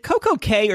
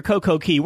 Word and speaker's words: CocoCay: James, is it Coco Cay or Coco Key? What are you calling it? – I call it Coco Cay, but I hear it pronounced the both ways.